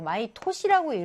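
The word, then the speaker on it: 마이 토시 (毎年): 근데 여기서 마이 츠키, 마이 개츠라고 안 읽고 마이 넨이라고 안 하고 마이 토시라고 읽어요.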